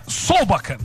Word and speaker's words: Solbakken: É o Solbakk, ou Solbakk, eu não me lembro a pronúncia correta dele, acho que é Solbakk, né? É isso? – É, Solbakken.